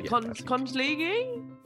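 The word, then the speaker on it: conchiglie: Yeah, Kondligi? – Yes. – Great, great pronunciation there. Yeah. – Kondligi!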